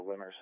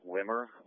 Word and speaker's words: Wimmer: Can you give me a patrol investigation for a Winter Cemetery? It's off of Dixon Road. – Leonard Cemetery? – I think it's pronounced Wimmer. William, I don't marry Mary ever, Robert.